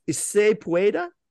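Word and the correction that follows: sí se puede: Oh, si se puede, which means yes, we can.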